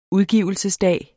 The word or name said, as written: udgivelsesdag